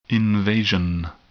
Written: invasion

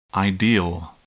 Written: ideal

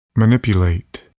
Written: manipulate

